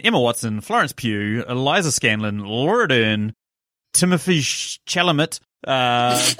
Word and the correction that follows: Timothée Chalamet: Timothée Chalamet.